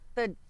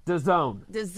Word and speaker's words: DAZN: DAZN. – DAZN.